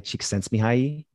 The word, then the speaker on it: Csikszentmihalyi: My favorite book probably is Flow, The Psychology of Optimal Experience by Mihaly Csikszentmihalyi.